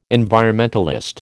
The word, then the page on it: environmentalist: American English